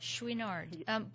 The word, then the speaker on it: Chouinard: Tell me, other than Yvonne, and is it pronounced Chouinard? Is that how his last name is pronounced? – Chouinard.